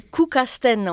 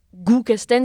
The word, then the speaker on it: Guckkasten: Alors, on va essayer de le prononcer comme il faut cette fois-ci c'est Koukasten en fait. Et non, Koukasten. – Et oui, et non, euh, comme ça s'écrit dans notre alphabet à nous, ça ne se dit pas Goukasten, c'est Koukasten. Koukasten.